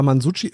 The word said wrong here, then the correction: Masucci: äh, wird er so richtig ausgesprochen? – Mansucci. Mansucci. – Mansucci. – Masucci. Ja. Aber auch wirklich gut gespielt, mit, mit, mit Hochgenuss.